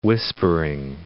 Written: whispering

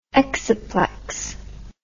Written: exciplex

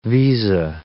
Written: visa

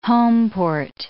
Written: homeport

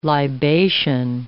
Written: libation

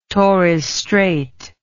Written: torres strait

